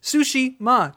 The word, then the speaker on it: Tsushima: You say Tsushima way better than Jeff Keighley did at the Game Awards. That man mispronounced it like in four different ways. It was embarrassing. It's like, it's Tsushima, dude.